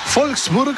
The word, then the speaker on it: Wolfsburg: No, ese Wolfsburg, porque la W se pronuncia como V corta, ¿no? O V, se dice V. Y la V, que es la B, la V se pronuncia F, por eso se dice von der, von en el alemán.